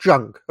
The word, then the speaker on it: Cenk: And I mean, Bastani, I mean, one of the things that's been happening online is that, it's pronounced junk. Okay, sorry, it's not Cenk, it's junk.